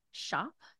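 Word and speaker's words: Tschopp: I think it's Kelsey Schopp. It's T-S-C-H-O-P-P. And if I'm saying that incorrectly, I apologize.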